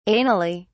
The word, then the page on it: anally: British English